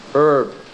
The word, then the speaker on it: herb: If you're outside of Britain, if you're American, American pronunciation is herb.